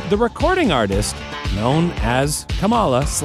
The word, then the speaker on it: Kamala: And despite what mainstream media would tell you, it's pronounced Kamala.